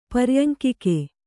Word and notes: ಪರ್ಯಂಕಿಕೆ: kannada

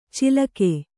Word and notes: ಚಿಲಕೆ: cilake

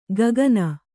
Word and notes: ಗಗನ: gagana

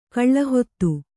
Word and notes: ಕಲ್ಹತ್ತಿ: kalhatti

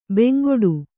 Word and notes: ಬೆಂಗೊಡು: bengoḍu

